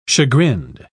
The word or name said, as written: chagrined